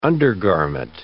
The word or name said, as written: undergarment